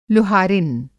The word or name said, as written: लुहारिन